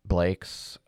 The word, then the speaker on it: Blaix: And just a bit, it's basically just like Blake's, but it's spelled B-L-A-I-X.